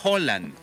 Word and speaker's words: Haaland: Porque ayer estaba viendo, fíjate, y no se dice Haaland, se dice Haaland. – Haaland. – Sí, me apareció un noruego que medio habla español en las redes sociales y dice que todo el mundo estamos pronunciando mal la palabra. ¿Por qué? Porque cuando hay dos O juntas, es como un O.